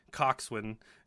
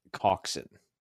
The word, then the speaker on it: Coxwin: So while Carwin and Coxwin go over to, wow, that's Carwin and Coxwin? Seriously? – All it's, right. It's actually technically pronounced Coxin. Like, so Coxin.